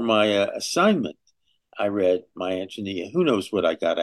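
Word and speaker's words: Ántonia: And she recommended to me My Antonia, and said, as pronouncing it correctly, Antonia, and said, this is a book about a girl.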